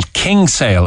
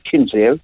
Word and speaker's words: Kinsale: A lot of people in Cork call Kinsale Kingsale as well. Put the Kingsale. G into it. I think that's the old pronunciation from way back when. – All the Norries call it Kingsale. – Kingsale, yeah. – The Sorries call it Kinsale.